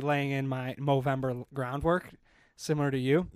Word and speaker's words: Movember: Movember. It's Movember.